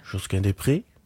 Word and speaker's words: Josquin Desprez: Josquin Desprez.